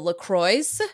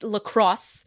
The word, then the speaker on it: LaCROS: Over the weekend, I kind of read a little bit about what I call LaCroix. That's how you pronounce it. It's spelled LACROS. It stands for Linux Chromebook Operating System, something like that. It's LaCroix.